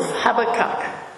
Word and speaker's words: Habakkuk: The book of Habakkuk, or Habakkuk, depending on which pronunciation you like. I grew up with Habakkuk, so I'll stick with that. But apparently, more recently, it's become Habakkuk.